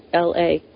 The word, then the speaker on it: Paola: She's not Paula. She's not. Paola. She's Italian. It's Paola.